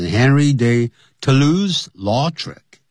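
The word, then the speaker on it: Henri de Toulouse-Lautrec: 啊，通常呢，就是直接也就会念成 Henry de Toulouse-Lautrec，这是英文的念法，Henry de Toulouse-Lautrec captured it，他就捕捉到了这个画面了，在什么地方呢？In many of his paintings，在他许多的画作里头啊，他都有呢，把它这个画上去。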